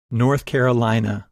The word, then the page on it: North Carolina: English